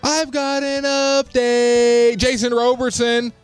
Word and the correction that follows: Robertson: Is it Roberson or Robertson? – I don't know. I say it wrong. – I feel like it's Robertson. – It's Robertson.